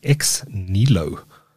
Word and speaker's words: xneelo: And that is Xnelo, pronounced or spelt X-N-E-E-L-O.